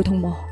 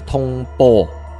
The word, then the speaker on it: 通魔: 那什么叫通魔？ – 发音一定要很准确，是通魔就不是通波。